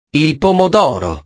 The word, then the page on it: il pomodoro: italiano